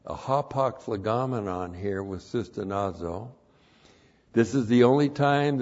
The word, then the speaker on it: sustenazo: S-U-S-T-E-N-A-Z, long O, sustenazo.